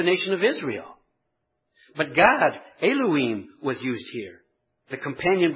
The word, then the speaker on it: Elohim: God comes from the Hebrew word Elohim, which if you want to put this in your notes, it's spelled E-L-O-H-I-M, Elohim.